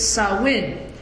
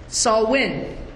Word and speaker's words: Samhain: There's a Celtic word here on the board, an old Celtic word, that bottom part of it is the pronunciation, the proper pronunciation, Samhain. Now, I know, personally, I've been tempted to say Samhain, but truthfully, it's Samhain. Samhain.